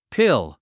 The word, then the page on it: pill: píl; ピル